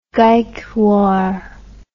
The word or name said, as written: gaikwar